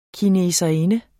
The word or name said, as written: kineserinde